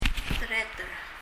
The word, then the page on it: tereter: [tɛrɛ(:)t(ɛ)r]